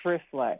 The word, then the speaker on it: Triphala: So first, one of my favorite herbs is Trifla. It's spelled T-R-I-P-H-A-L-A.